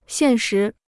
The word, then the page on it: 现实: xiàn shí